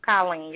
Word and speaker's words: Colleen: Is it Colleen or Colleen? – Oh, no, it's Colleen. You're right. You got it.